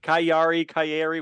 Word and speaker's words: Cagliari: And then that Italian team that I can't pronounce it because apparently G's are pronounced as Y's there. Cagliari, whatever it is.